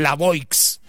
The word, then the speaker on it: La Voix: Se pronuncia así, tal cual, la Voix.